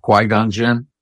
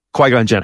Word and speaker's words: Qui-Gon Jinn: I always mispronounce that character's name. – Well, you'll accept my pronunciation of Qui-Gon Jinn? – Qui-Gon Jinn, absolutely.